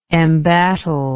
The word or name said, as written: embattled